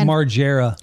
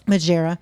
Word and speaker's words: Margera: Reports say they made contact with Margera. However you say his name. – It's Margera. – Margera.